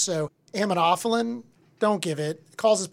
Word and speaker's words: Aminophylline: Aminophylline.